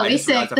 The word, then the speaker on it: Wasick: Yeah, Wasick, the soft C. Well, people think it's chick or kick, but it's a soft C, baby.